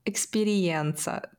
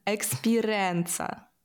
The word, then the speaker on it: Експіренса: Експірієнса. Типу, як експірієнс. – Ну, да. – А він не так називається. Він називається експіренса.